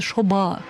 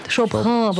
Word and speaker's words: Шобха: Когда фамилия, имя ее действительно пишется словами шопха, но на самом деле произносится шоба. – Шоба. – Да. – Прошу прощения. – Шобха. Вот это не такое ха, как у нас. – Ну, как Махашоба. Мне придется долго тренироваться. – B-H-A.